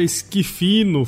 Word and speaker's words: Schifino: pessoal, tô de volta com a Eduarda Esquifino. Falei certo, né, Eduarda? – Isso mesmo.